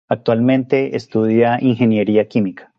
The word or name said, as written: estudia